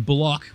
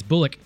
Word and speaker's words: Bullock: Reggie Bullock, they insist on pronouncing his name Block, which is fucking weird. That's Bullock. 13 points, 3-3 from 3, but that was about it.